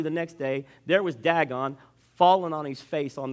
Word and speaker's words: Dagon: Their god is D-A-G-O-N. There's debate how you pronounce it, Dagon or Dagon. Well, where I'm from, we always say Dagon. Dagon it. Dagon. So I will use that pronunciation.